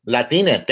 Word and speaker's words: Latine: Am I saying that right? – Latine. – Latine. Thank you for correcting me.